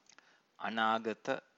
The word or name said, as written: anagata